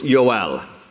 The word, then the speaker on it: Yoel: His name is given to us here, but there's no J in the Hebrew alphabet. And so his name is really with a Y. It's Yoel. And Yoel puts the emphasis on the E-L,